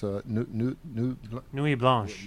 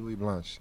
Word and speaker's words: Nuit Blanche: Nuit Blanche. – Yeah, Nuit Blanche.